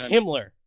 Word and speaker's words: Himmler: Hey, speaking of Hibbler, those robots were kind of doing a little goose-stepping there. That was kind of cool. – Yeah. – But, it's pronounced Himmler.